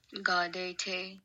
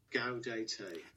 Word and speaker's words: Gaudete: Gaudete. – Gaudete. Another one? – Gaudete. – Gaudete. Gaudete, Gaudete, depending on what part of the world you're from, how you pronounce Gaudete. That's spelled G-A-U-D-E-T-E. Gaudete Sunday.